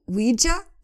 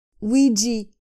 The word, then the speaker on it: Ouija: Vamos falar do objeto misterioso do Ouija, o Ouija board, pronunciado Ouija ou Ouija. Ok. Ouija board. Eu sempre disse Ouija, nunca disse Ouija, mas há quem diga Ouija.